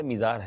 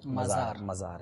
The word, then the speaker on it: مزار: یہ مزار ہے یا میزار ہے؟ – میم پر زبر ہے، ٹھیک ہے، مزار ہے، ٹھیک ہے، تو یہ بھی پروناؤنسیشن ہمارا تلفظ درست ہونا چاہیے، یہ مزار شریف ہوتا ہے،